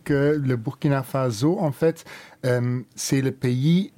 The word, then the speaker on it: Faso: Faso, c'est normalement Faso. Oui. Mais en français, comme c'est un seul S, beaucoup disent Faso, ah. Mais nous, on le prononce Faso. – Faso. – Parce que c'est en Djoula, et ça veut dire la maison du père.